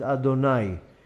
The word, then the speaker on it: Adonai: Then at night, the name of Hashem is the name Aleph Dalet Nun Yud that we pronounce as Adonai.